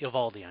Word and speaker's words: Eovaldi: They say Eovaldi on TV. – Eovaldi is, I think you're right, they make that one syllable.